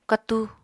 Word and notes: katu: Apatani

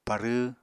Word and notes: parww: Apatani

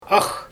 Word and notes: ax: Xyzyl

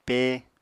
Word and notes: pé: apatani

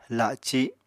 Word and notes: la7ci7: Apatani

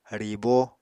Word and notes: ribo: Apatani

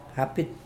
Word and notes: apid: Mokilese

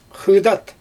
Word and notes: xɯjdat: Xyzyl

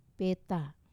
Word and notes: peta: wamesa

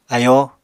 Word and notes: ayóo: apatani

